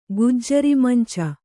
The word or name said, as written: ಗುಜ್ಜರಿಮಂಚ